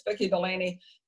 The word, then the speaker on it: Vicki Delany: If your listeners want to look for me, my name is spelled a little differently. It's Vicki Delany. Vicki with an I, but Delany is D-E-L-A-N-Y, not E-Y, which is the common spelling.